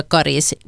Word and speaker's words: Karis: Rekommendationen om långt a karis har funnits sedan början av 1960-talet, och för det så fanns det faktiskt inte uttalsrekommendationer överhuvudtaget. Samtidigt är uttalet med korta troligen äldre, ofta är det talspråkliga uttalet äldre än det officiella.